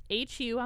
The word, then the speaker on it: Hu: So for our listeners out there that haven't heard of Hugh, it's H-U on the box, and it stands for human, right? – I think so. – Some people call them Hugh.